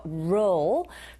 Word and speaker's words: role: 이때 role 역시 발음했을 때 R 발음으로 시작하니까 우 하시면서 roll. 근데 휴지 두루마리 할 때 그 roll도 같은 roll이거든요. 네, 철자는 다르지만 소리는 똑같다는 거 염두하시면서 기억해 두시고요.